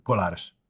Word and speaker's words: Colares: I can't pronounce that. Say that. – Oh, you did great. Colares.